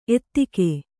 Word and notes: ಎತ್ತಿಗ: ettiga; Kannada

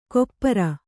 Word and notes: ಖೊಪ್ಪರ: khoppara

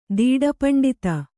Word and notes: ದೀಡಪಂಡಿತ: kannada

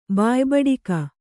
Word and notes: ಬಾಯ್ಬಡಿಕ: bāyb aḍka

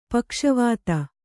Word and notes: ಪಕ್ಷವಧ: pakṣa vadha

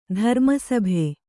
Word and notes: ಧರ್ಮಸಭೆ: dharma sabhe; Kannada

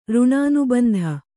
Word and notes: ಋಣಾನುಬಂಧ: řṇānubandha

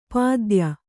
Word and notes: ಪದ್ಯೆ: padye

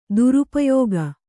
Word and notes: ದುರುಪಯೋಗ: kannada

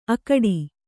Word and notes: ಅಕ್ಕಾಡು: Kannada

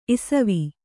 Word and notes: ಇಸ್ವಿ: isvi

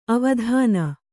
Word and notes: ಅವದಾನ: Kannada

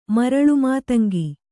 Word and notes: ಮರಳುಮಾತಂಗಿ: kannada

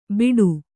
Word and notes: ಬಿಡು: Kannada

